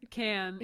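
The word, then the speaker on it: Cannes: Because there's like little smaller festivals throughout the country and throughout the world, but there's the I forget how to say it. – Can. – Yeah. Because it looks French. I'm like, can? Con?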